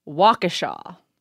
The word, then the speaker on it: Waukesha: He very clearly spelled out how it should be pronounced. Waukesha. He was like, I grew up there. And you pronounce it WALK-AH-SHAW.